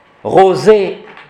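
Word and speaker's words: rosae: Pour terminer ici, Rosa, Rosa, Rosam, Rosé, A-E, ça se dit E.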